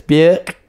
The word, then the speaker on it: Björk: No, you say it like this. Björk.